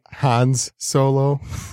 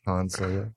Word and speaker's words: Han Solo: I said Hans Solo. – Hans Solo? – Yeah. – I wish I was there. That's the Swedish Star Wars, Han Solo.